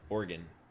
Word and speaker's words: Oregon: Is that how you pronounce it? Is it Oregon or Oregon? – Oregon.